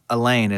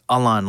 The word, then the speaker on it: Alain: And I'm like, she says, it's not Elaine, it's Alain. Like, his English name would be Alan.